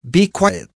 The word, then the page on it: be quiet: American English